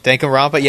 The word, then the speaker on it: Danganronpa: And now there's three of those, how you say it, Danganronpa? – Danganronpa? – Yeah, I really want to play it.